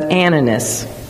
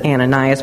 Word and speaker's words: Ananias: I would actually pronounce it Ananias, but Steve Davies pronounced it Ananias.